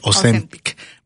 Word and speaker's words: Ozempic: Ozempic.